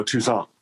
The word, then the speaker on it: Toussaint: How do you say that? Toussaint? – Oh, Toussaint. – Toussaint. Thank you. – It's French. – Yeah. – It's like like croissant, you know. – Croissant, but Toussaint.